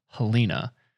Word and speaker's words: Helena: I've been saying it wrong. But it's Helena for those that didn't know.